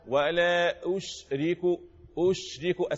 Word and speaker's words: ولا أشرك: ركز معايا قل إنما أدعو ربي ولا أشرك الشين اللي فيها شنشنة هي التفشي اللي في الشين انتشار الهواء الريح اللي في الفم اهو ولا أشرك اسمحت نطق ازاي ها